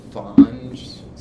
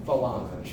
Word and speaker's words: Falange: It was like the Fange? Is that how this was? – I can't accept that. I totally butchered that. – You did. – National Front? – No. Falange. – Falange. I missed a syllable, damn it.